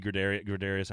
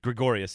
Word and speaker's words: Gregorius: Gregorius, I love watching some of these guys. I love Aaron Judge. Gregorius, sorry, I said his name wrong.